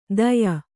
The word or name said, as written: ದಯ